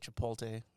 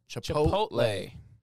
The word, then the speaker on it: Chipotle: Imagine this picture, but it says society would be like this if boomers could pronounce Chipotle. – I don't care. – The T is before the L.